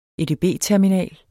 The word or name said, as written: edb-terminal